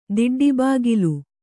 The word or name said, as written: ದಿಡ್ಡಿಬಾಗಿಲು